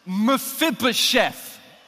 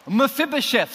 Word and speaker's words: Mephibosheth: The name is pronounced Mephibosheth. Mephibosheth. So, can you please say that with me after three? One, two, three. Mephibosheth.